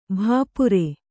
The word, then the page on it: ಭಪ್ಪರೆ: bhappare